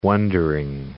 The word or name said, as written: wondering